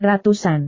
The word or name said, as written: ratusan